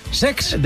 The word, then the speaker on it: Cesc: El Como de Cesc Fàbregas, que se estrenó... – Lo he dicho mal, es Sex.